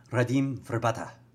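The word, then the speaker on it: Radim Vrbata: Radim Verbata. That's how it's pronounced.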